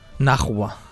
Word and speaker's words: Najwa: Naunja Nimri. – Sí? Naunja? – Naunja. – Cada vegada ho diem diferent. Najwa. – Najwa. – S'escriu N-A-J-W-A. – Najwa. – Najwa.